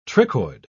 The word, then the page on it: trichoid: British English